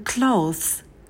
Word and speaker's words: clothes: Гладить одежду это iron clothes. Clothes. В слове clothes на «к» добавляем придыхание. Довольно-таки сложное слово, наверное, одно из самых сложных слов в английском языке.